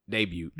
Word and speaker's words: debut: debut. The T is silent.